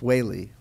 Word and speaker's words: Witte: That's how it's pronounced. It's spelled, though, W-I-T-T-E, but according to Google, it's pronounced Waly.